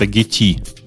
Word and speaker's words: Gitea: Кстати, обратите внимание, что судя по мерчу, читается это Getty. – Ага. – Просто у них на, на логотипе э, в мерче чашечка с э, пакетиком, видите, внутри. Ну, наверное, наверное, оно так и читается.